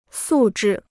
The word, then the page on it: 素质: sù zhì